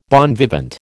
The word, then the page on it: bon vivant: American English